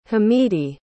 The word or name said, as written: hamidi